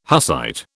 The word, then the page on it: Hussite: British English